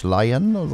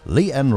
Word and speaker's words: Lianne: Lianne, ja, möchte sie genannt werden, ja, geschrieben tatsächlich L-I-A-N, also müsste man eigentlich sagen Lianne, ja, wie es aber im Englischen so ausgedrückt wird, aber hier möchte sie anders genannt werden, ja, nicht Lianne, sondern Lianne möchte sie ausgesprochen werden.